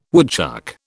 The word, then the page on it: woodchuck: British English